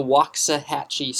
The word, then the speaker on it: Waxahachie: Is that how you pronounce it? – Yeah, that sounds close enough. – The Waxahachie swap.